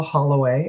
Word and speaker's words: Holloway: Of course, if you want to look me up, it's Joshua Holloway, and the last name is spelled H-O-L-L-O-W-A-Y.